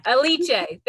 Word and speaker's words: Aliche: I'm just saying, because the Budgetista Tiffany Ariche, Ariche, how do you say Aliche. There we go. Aliche. I always put too many syllables.